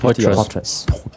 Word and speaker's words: Poitras: Wie spricht man das eigentlich? – Poitras. Ja. – Poitras.